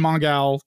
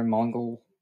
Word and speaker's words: Mongal: Yeah, yeah, um, I like that they pronounced her name with the gal at the end, and not just calling her Mongol.